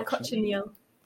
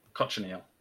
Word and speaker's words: cochineal: I wondered if we could start at the beginning and have a reading of Cochineal Wings. Am I saying that right? – Yeah, Cochineal. – Cochineal.